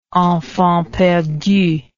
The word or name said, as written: enfants perdus